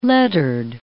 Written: lettered

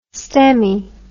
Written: stemmy

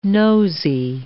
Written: nosey

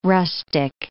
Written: rustic

